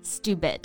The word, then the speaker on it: stupid: So one of the most commonly used words for this is stupid. 没错，我刚刚呢就用到了这个词哈。I made a stupid mistake. Stupid. S-T-U-P-I-D. 那要注意啊，这里的字母 T 跟在字母的 S 后面会有一个不送气的啊，这么一个发音，我们读成类似于的这个发音哈。Stupid 就是一个很基本的用来形容人很笨呀很傻的这么一个单词。